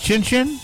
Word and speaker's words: Xin Xin: Chin Chin, that's spelled X-I-N-X-I-N. So that's like Chinese Chin Chin.